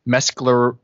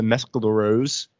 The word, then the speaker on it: Mescaleros: This is with the I think it's pronounced the Mescaleros. Joe Strummer and the Mescaleros.